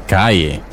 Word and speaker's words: calle: The word for street in Spanish is calle. Calle. So, streets are calles. Calles. Calle is spelt with two L's. In Spanish, we pronounce two L's as a Y sound.